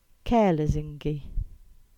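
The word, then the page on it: Lesingey Round: Cornish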